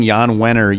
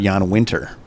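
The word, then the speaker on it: Jann Wenner: Multiple times calling Jan Wenner Jan Winter. From Rolling Stone. – Oh. – That's my most humiliating mispronunciation.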